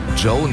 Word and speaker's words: Djo: Joe nennt er sich. Und es ist Joe Keery, spielt in Stranger Things. – Ja, und Joe schreibt man D-J-O.